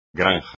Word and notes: granja: espagnol

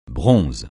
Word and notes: bronze: francais

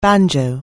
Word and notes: banjo: anglais

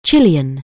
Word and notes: Chilean: anglais

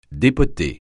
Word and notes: dépoter: francais

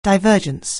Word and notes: divergence: anglais

